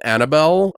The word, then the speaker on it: Annable: It's A N N A B L E. It's not Annabelle B E L. – Annabelle. – Annabelle. Yeah, I guess like Hannibal. I don't know.